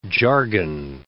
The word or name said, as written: jargon